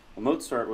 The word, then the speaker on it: Mozart: Mozart was a composer. He's a well-known composer. He's a composer whose name is often mispronounced by English speakers who don't know that the Z has a TS sound.